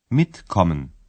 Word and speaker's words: mitkommen: در تلفظ فعل‌های پیشوندی تکیه روی جزء پیوندی است. – mitkommen mitkommen.